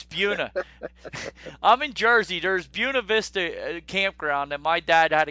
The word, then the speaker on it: Buena Vista: Buena Vista.